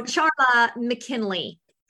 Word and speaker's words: Charla McKinley: No. It's Charla. I was supposed to be a boy. Charlie, surprise, but I'm a girl. So Charla McKinley, like the okay.